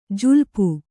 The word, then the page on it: ಜುಲಿಪೆ: julipe